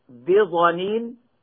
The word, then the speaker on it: بضنين: ولهذا وردت القراءة في كلمة بضنين بالضاد من الضن وهو البخل أي ببخيل، ووردت بالضاء من الظن هكذا، وما هو على الغيب بظنين أي بمتهم.